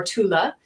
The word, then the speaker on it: Thule: Is it Tula or Thule? The Ultima Thule? – Oh, Thule Society? – Yeah. – T-H-U-L-E. – Oh, yeah, exactly.